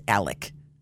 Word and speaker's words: Alex: Well, today, a convicted killer, Alex Murdaugh, it's Alec. And he spells it A-L-E-X, but they pronounce it Alec.